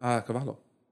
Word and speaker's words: cavallo: How do you say it in Italian? – Cavallo.